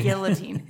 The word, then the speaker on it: guillotine: Guillotine. It's a French word, so you don't pronounce the double L.